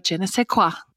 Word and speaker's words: je ne sais quoi: It's like that little extra je ne sais quoi.